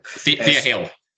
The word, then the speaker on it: Thea Hale: Chris does have Tiffany Stratton on Sunday night defending the NXT Women's title against Tia Hall. Or Tia Hale, I can't remember how you pronounce her name. – Thea Hale.